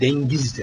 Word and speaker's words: deniz: Onlar dengiz derler. Dengiz. Orada NG sesi vardır esasen.